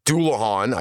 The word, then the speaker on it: Dullahan: Some of them include the Dublacan, which is often pronounced in English as Dullahan. I don't know why they even said that, because the spelling is not anywhere close.